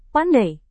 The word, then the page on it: pandai: Indonesian